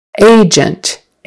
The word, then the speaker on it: agent: Agent, stress on the first syllable, and it's an A, like the letter A, a long A sound.